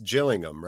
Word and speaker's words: Gillingham: Yeah, I'm just looking right now at his senior career, and it's pronounced Gillingham, right? Is that right? – Yeah, that's it. Yeah, Gillingham, yeah. – Ooh, look at me pronouncing things right. I swear for 20 years I called it Gillingham. I only realized the ham thing learning through all you wonderful human beings, which is why we do this show.